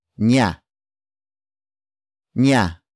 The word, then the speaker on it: niya: Niya. Niya.